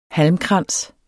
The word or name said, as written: halmkrans